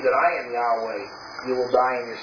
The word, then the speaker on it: Yahweh: Sometimes we call it Jehovah, but it would have been pronounced more like Yahweh.